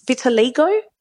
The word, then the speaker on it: vitiligo: vitiligo.